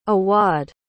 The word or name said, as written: awadh